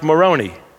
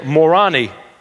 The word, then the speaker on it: Moroni: They say his name is pronounced Moroni. I would say Morani is probably more appropriate.